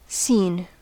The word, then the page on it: seen: /siːn/